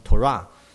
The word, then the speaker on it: Torah: 律法书呢就是在这个他们叫做妥拉，他们读成 Torah，就是指摩西五经。